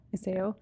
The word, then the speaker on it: Iseo: I was pronouncing Look, it in Spanish, Iseo. It'd be like Iseo. Iseo. – Iseo.